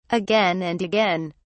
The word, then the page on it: again and again: British English